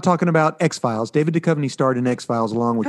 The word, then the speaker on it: Duchovny: Duchovny? – David Duchovny.